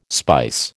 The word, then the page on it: spice: American English